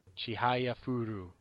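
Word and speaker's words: Chihayafuru: Chihaya Furu.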